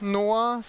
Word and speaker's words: NOAA: For information, it's actually pronounced NOAA, so it's the National Oceanic and Atmospheric Administration, which is pronounced NOAA, if you can read the nice symbols here.